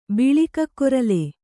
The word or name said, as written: ಬಿಳಿಕಕ್ಕೊರಲೆ